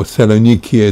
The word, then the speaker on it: Saloniki: And I admired the Salonika, or Saloniki, as we pronounced it at that time, sound.